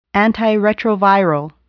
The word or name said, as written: antiretroviral